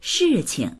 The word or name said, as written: shi4qing